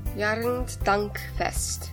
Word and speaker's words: Erntedankfest: They have a big autumn festival there, and it's called Erntedankfest. – Erntedankfest. – Oh, very good pronunciation.